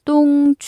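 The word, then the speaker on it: 똥 (冬): 자, 이걸 다 이어붙여서 똥, 취, 춘, 라이 라고 하시면 됩니다. 자, 겨울이 간 지 언젠데 아직 춥냐 이런 생각이 들 때는 겨울이 완전히 떠나야 봄이 온다는 의미에서 똥, 취, 춘, 라이, 똥, 취, 춘, 라이 라고 말씀해 보세요.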